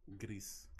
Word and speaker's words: Gris: How do you say it? Gris. Gris. Okay, so that's the Spanish word for gray.